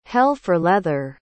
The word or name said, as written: hell-for-leather